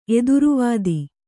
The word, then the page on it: ಎದುರುವಾದಿ: eduru vādi